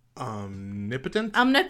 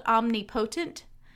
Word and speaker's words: omnipotent: Because it's spelled omnipotent. – Yeah. – And as a kid, I can remember saying omnipotent and everyone being like, and I'm like, what?